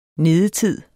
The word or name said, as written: nedetid